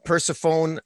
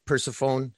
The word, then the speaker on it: Persephone: There are scenes, the introduction of Persephone, I mispronounced that, didn't I? – Persephone. – Persephone, thank you.